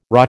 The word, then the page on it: RHA: American English